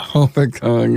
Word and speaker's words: Hopatcong: Did I say that right this time? – Hopatcong, yeah, golly.